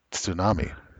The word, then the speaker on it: tsunami: And I should know better because most people do think, like, you know, one of the loan words in English, it has that syllable to start it out. It's tsunami. – Right. – And most people, I heard it go growing up. You know, oh, the two silent. No, it's fucking not.